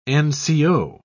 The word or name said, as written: nco